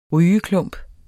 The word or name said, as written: rygeklump